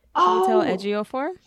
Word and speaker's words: Chiwetel Ejiofor: Chiwetel Ejiofor. – Yes. – Chiwetel Ejiofor.